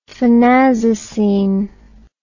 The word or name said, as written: phenazocine